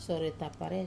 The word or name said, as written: sore tapare